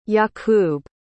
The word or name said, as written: yacoub